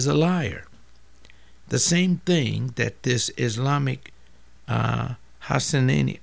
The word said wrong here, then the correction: Hassanain: I'm not saying his name properly, and I'm so sorry, Hassanain.